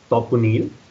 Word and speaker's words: Taponeel: So being a Bengali, the name would be pronounced as Taponeel, but I hear different variations of that, so that should be fine for the interview.